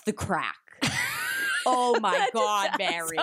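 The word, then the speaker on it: craic: Like, the wedding itself started at 1:30 and then crake. Do you know crake? Good fun is crake. – You're pronouncing it fucking wrong. Holy shit. Is it? It's the crack. Are you having the crack? It's C-R-A-I-C, the crake.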